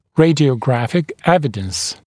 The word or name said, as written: radiographic evidence